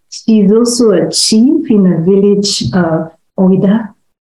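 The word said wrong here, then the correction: Ouidah: I don't know if I pronounced that correct. Correct me, Iya. – Wida. – Wida.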